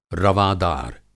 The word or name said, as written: रवादार